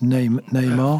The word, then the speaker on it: Namir: So, Florrie Namer, N-A-M-I-R, just in case I got it wrong, because, you know... – Yeah, I think it's pronounced Namer.